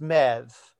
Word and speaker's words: Medbh: The Irish is that the spelling always hurts my head because it's like Medbh or something like that. And it's apparently pronounced Mev.